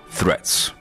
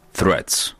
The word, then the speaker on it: Threads: How to pronounce the word threads? You start with a voiceless TH sound, stick the tongue out, release air, then pull the tongue in for the R. Thr, make sure the tongue doesn't touch the upper palate and round your lips.